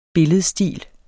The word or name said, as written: billedstil